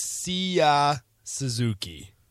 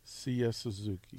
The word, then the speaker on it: Seiya Suzuki: I believe it's Seiya Suzuki. – Seiya Suzuki. – Seiya Suzuki.